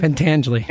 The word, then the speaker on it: Pentangeli: Except Frankie, how do you say the name again? – Pentangeli. – Yeah, Pentangeli's brother didn't speak.